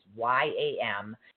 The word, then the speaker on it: yam: for this one is yam, which is Y A M, and it's pronounced yam.